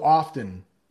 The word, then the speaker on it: often: Brent, in my memories, I've been learning at school, we don't pronounce the T in often. Yeah. Often, you might see it written as a T, but we actually pronounce it with a D. There are a lot of words like that. So often, it's almost like no sound there or a D sound, like often.